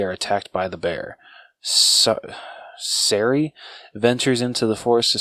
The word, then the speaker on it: Sari: S-A-R-I. Sorry? – Yeah. I'm gonna say Sari.